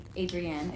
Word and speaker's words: Adrienne: But it's meant to be pronounced like a lady. Adrienne. It's a French ending. E-N-N-E.